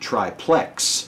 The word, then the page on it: triplex: American English